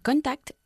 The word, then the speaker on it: contacted: I've contacted all the people. – Contacted, porque acabo en T el verbo, y por eso pronunciamos la E final del pasado. – Okay. – O en este caso, del participio.